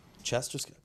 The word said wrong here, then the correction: Jester: Jester. – Jester. – With a J. – Okay. – A hard J.